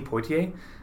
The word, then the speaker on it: Poitier: In his place, he suggested Sidney Poynter, despite the fact that Poynter was not a musical performer. – Wait, Sidney Poitier? – Yes. Poitier, sorry.